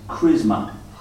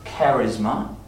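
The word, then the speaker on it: chrisma: And then the other word, be careful how you pronounce this, it is charisma. Not charisma, as in charismatic, because that word comes more from the word grace.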